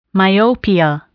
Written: myopia

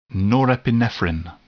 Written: norepinephrine

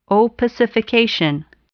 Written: opacification

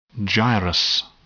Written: gyrus

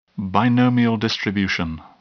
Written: binomial distribution

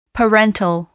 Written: parental

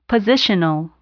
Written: positional

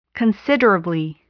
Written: considerably

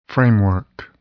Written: framework

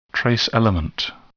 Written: trace element

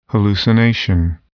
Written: hallucination

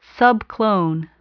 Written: subclone

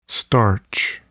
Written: starch

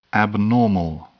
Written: abnormal

